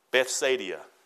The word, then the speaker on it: Bethsaida: unto Bethesda. Bethesda. Did I say that right? Bethsaida. Sound better?